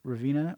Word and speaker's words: Ravenna: Did I say that right? Ravenna, R-A-V-E-N-N-A.